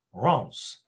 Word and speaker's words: Reims: But it's actually pronounced Reims with no M.